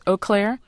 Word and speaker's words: Eau Claire: I'm not sure I'm pronouncing this right. It's Eau Claire, Wisconsin. – Yes.